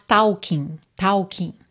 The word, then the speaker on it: talking: E o L dessa palavra talk também não é pronunciado. A gente não diz talk, fica talk, tá bem? Então tem que tomar bastante cuidado para não soar talking, tá? Talking. Pensa talk. Uhum. Talking, o G não é pronunciado, então talking to the moon.